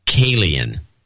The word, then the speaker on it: Kalian: And it's Kalian. Rhymes with alien, Babcock. Kalian.